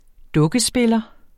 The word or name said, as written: dukkespiller